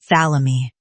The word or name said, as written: thalami